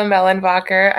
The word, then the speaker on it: Mehlenbacher: Hello, Dr. Carla Mellenbacher. I know the last name looks intimidating, but it's the silent H's.